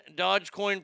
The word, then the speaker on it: Dogecoin: I own Bitcoin, Dogecoin pronounced sort of like Dojo, but without the O at the end as well, and some other cryptocurrency.